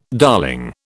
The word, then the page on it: darling: British English